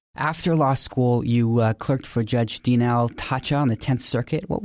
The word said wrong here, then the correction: Tacha: Yeah, forgive me for correcting the pronunciation, but she pronounces it Taha.